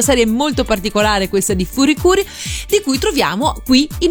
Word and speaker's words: FLCL: scritta così, ma la pronuncia è Furikuri.